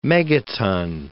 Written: megaton

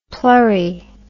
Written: plurry

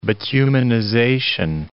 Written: bituminization

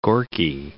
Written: Gorki

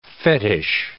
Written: fetich

